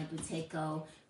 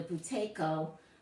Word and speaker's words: Buteyko: I guess I'll spell it for you because I don't even know if I say it properly. B-U-T-E-Y-K-O. B-U-T-E-Y-K-O. Buteyko is how I say it. I don't know if I'm right.